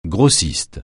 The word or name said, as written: grossiste